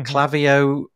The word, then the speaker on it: Klaviyo: Is it called Klaviyo? Is that how you pronounce it? Mm-hmm. Klaviyo is coming, um, and ConvertKit is coming.